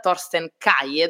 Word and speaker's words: Thorsten Kaye: Eh, c'era l'attore che interpreta Ridge Forrester, Thorsten Kaye, dovrebbe pronunciarsi così perché lui in realtà è tedesco